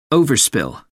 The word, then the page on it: overspill: American English